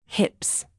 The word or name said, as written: hips